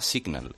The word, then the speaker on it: SGNL: Se llama Signal y se escribe S-G-N-L, porque se escribe sin vocales.